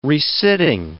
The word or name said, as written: resitting